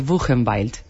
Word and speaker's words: Buchenwald: Es Ilse Koch, la zorra de Buchenwald.